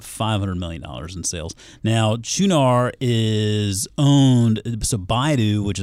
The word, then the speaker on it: Qunar: It's Qunar. It's spelled Q-U-N-A-R, but I believe it is pronounced Qunar.